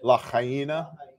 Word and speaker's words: Lahaina: Lahaina. Yeah. So it's Hawaiian. So there's six letters in the word and it has four syllables.